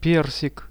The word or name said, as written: persik